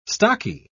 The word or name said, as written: stocky